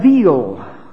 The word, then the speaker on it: evil: Evil would be the way it would be pronounced. In English letters, it would be spelled like evil, E-V-I-L.